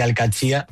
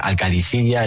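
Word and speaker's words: Al-Qadsiah: Bueno, se suele decir Al-Katsia. – Al-Katsia. – Pero también hay gente que dice Al-Kadisiya.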